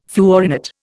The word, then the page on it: fluorinate: Indian